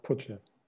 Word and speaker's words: Putsche: Yeah, it's called Putsche.